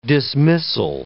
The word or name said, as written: dismissal